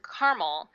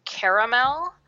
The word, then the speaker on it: caramel: So I pronounce the word caramel, which is how it's typically pronounced on the West Coast, if you look at language dialect maps. And but people on the East Coast absolutely believe it should be caramel, three syllables, or caramel.